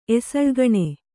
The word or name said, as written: ಎಸಳ್ಗಣೆ